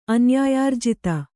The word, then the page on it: ಅನ್ಯಾಯಾರ್ಜಿತ: kannada